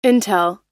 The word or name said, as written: intel